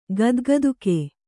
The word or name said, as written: ಗದ್ಗದುಕೆ